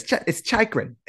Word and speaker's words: Chychrun: I can also already hear all the Long Islanders mispronouncing his name. – Yeah. – Oh. – Yeah. Come on, it's Chychrun.